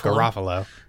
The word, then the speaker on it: Garofalo: Garofalo.